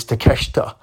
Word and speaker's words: Takeshita: Up next, we have the Golden Elite going up against Bullet Club Gold, and Takeshita is how his name is spelt, but it's Takeshita.